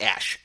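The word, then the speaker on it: X Æ A-12: X... A-E, which is like the... It's pronounced Ash. X-A-12, but they just changed it to -XII, like Roman numerals. Still A-12. It's still pronounced the same way, but X-A-12.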